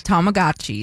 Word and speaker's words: Tamagotchis: Tamagotchis.